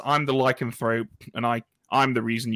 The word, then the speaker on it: Lycanthrope: Lycanthrope, yeah.